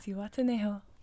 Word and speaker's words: Zihuatanejo: The Zihuatanejo shrimp. – Zihuatanejo.